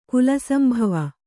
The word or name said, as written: ಕುಲಸಂಭವ